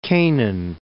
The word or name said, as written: Canaan